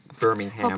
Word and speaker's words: Birmingham: But Birmingham.